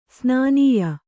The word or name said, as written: ಸನ್ನಾಯ್ಯ